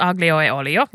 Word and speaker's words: aglio e olio: How do you say it? Aglio, meaning garlic in Italian, e olio. Aglio e olio.